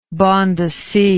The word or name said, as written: banda sea